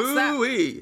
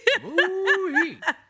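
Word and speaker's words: Mooi: Pronounce Woo-wee. – That. – Woo-wee.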